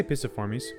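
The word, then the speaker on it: Piciformes: The order is Picciformes. – I say Pisciformes. A soft C.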